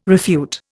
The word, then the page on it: refute: Indian